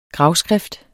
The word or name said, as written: gravskrift